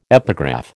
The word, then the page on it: epigraph: American English